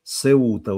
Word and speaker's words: Сеута: Так, значит, только извините, произносится «Сеута», ударение... А, «Сеута», «Сеута».